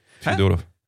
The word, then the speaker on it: Fjodorov: Je Moet, ja, daar ligt de, de, de klemtoon. Ja, oké. Maar is met een J ook. Vjedorov. – Ja, de, de E in het Russisch is, met, is een J. Fjodorov.